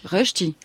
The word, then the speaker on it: Rösti: R au tréma, donc R-E-S-T-I, ch'ti. – La prononciation est bonne ou pas? – Rösti. – Rösti. – C'est ça? – Genau.